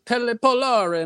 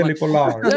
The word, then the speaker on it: Pelle Polare: He's got this Italian roll to it, man. Pelle Polare. And I'm Pelle Polare. That's perfect, man. I need to get you on the commercial. – Can you just record that real quick for me? Yeah, it's Italian. So and people have always fucked it up my whole life.